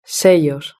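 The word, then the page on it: sello: European Spanish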